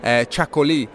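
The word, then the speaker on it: Chacolí: Chacoli, which I think I pronounced Chacoli in the wine podcast. I didn't realize there was an accent on the final I.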